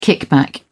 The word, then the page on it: kickback: British English